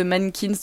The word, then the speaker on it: MNNQNS: vous pouvez écouter le nouvel album de Mankins. Donc je répète, ça s'écrit M-N-N-Q-N-S.